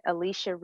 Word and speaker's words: Alechia: So, I am everywhere at Alechia Reese, and my parents got super excited with the spelling, so I'll spell it for you.